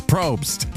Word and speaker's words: Probst: Is it Jeff Probst? Yes, Probst. P-R-O-B-S-T.